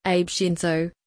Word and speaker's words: Abe Shinzo: Shinzo Abe, Abe Shinzo, pronounced Abe Shinzo, the 21st of September 1954, the 8th of July 2022.